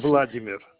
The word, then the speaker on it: Vladimir: Your first Vladimir.